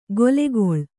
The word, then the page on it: ಗೊಲೆಗೊಳ್: golegoḷ